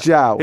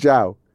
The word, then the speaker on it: João: João. – Who is Yao Pedro? – João. Is it João? – João Pedro.